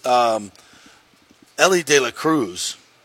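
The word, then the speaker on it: Elly De La Cruz: These young kids, Ellie De La Cruz, did I say that right? – You got it.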